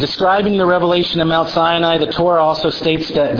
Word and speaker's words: Sinai: It's the same root word for sine, which we say is Sinai, but it's pronounced sine.